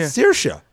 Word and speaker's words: Saoirse: How much trouble I got in with Patrick Barney McAleer over Saoirse Ronan. – Yeah, Saoirse Ronan. – Spelled S-A-O-R-I-S-E. Yeah. Saoirse.